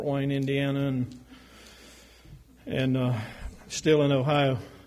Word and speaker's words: Ohio: And how do you say that, Martha? Ohio. Kind of run it together or something.